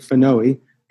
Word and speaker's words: PNOE: So I started to work with PNOE, P-N-O-E, and in Greek, it's pronounced, I think, phanoe.